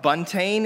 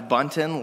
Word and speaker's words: Buntain: Buntain is a fairly popular pronunciation of my last name, but incorrect, slightly. It's Buntain, like mountain, kind of like that A-I-N at the end of mountain.